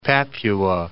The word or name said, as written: Papua